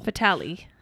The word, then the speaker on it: Fatale: Then on the 30th is Come Play from Focus and Fatal from Lionsgate. – Fatal. – Sorry. I always say it wrong. – Fatal. – Fatale. From Lionsgate.